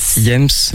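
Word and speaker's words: Hiems: Donc tu me disais ça se prononce Iems. – Iems, ouais, ça veut dire hiver en latin.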